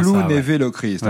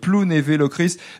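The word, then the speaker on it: Plounévez-Lochrist: Plounévé-Locrist, c'est bien Plounévé-Locrist. Ouais. J'ai fait attention, j'ai à peu près prononcé correctement, parce qu'il y a un Z à la fin, mais on dit Plounévé-Locrist.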